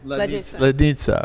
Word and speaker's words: Lednice: And we started, we went to a castle, or chateau, I should say, in Valdis called the Lednice Chateau. I don't know if I pronounced that right. – Yes, almost. – Lednice.